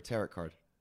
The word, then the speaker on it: tarot: I had a tarot card reading once, and it was so on point that You're not supposed to pronounce the T? It's tarot card or tarot card? – What?